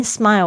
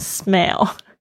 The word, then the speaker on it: smile: Number 7，哦，这边 smile 我要特别讲一下，千万不要讲成 smell，因为有一些同学那个 I 的发音发不好就会变成 smell， smell 是闻起来臭臭的，所以我们有时候说某人就是臭臭的，我们就说 You smell，就没有洗澡。